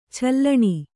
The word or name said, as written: ಚೀಲಣ